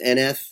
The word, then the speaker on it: NF: That's how you say it, just NF? – NF, yep.